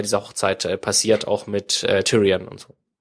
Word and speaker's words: Tyrion: Ich muss übrigens, Tyrion spricht man den aus. Das letzte Mal gab es schon viel Gemecker in den Kommentaren, dass wir den irgendwie 4 oder 5 Mal falsch ausgesprochen haben.